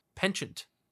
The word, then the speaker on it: penchant: They did, however, show their perchant... how do you say that word? Perchant? Penchant. Penchant? It's not an R, it's an N. Penchant.